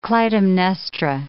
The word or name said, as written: Clytemnestra